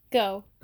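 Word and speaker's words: Gow: And the nanny's name was Betty, I hope I'm pronouncing this right, Grow? – Go. – Go? – Go. It's G-O-W.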